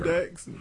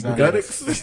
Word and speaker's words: Gudex: Nicki and N-I-K-I. – Minaj. – No. Last name is spelled G-U-D-E-X. – G-U-N-I-T. – She is a mountain. – Nicki Godot. – She is a mountain biker. – Gudex. – Nicki Gudex.